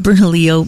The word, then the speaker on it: Bernalillo: You know, the town of Bernalillo, the town of Bernalillo, Mayor's 4th of July, All Ages Splash Fest.